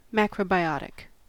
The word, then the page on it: macrobiotic: American English